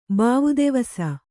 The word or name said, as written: ಬಾವುದಿವಸ